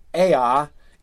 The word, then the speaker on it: Eä: So that's why, you know, so when Iluvatar says Ea, it's two syllables. That Ea, that one word that is Ea is two syllables, and that's indicated by the umlaut, the two.